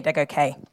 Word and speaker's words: Adegoke: Yeah, I'm Yomi Adegoke.